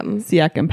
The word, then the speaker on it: Siakam: Siakam,